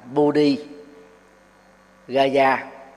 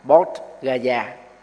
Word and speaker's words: Bodh Gaya: Trong tiếng Pali đó, Bồ Đề Đạo Tràng là Bodhi Gaya, và thường phát âm là Bodh Gaya.